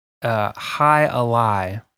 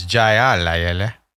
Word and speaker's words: Jai Alai: Today we're drinking the Hi Alai. – You might read that as Jai Alai. – Yeah. – But that's not how it's pronounced.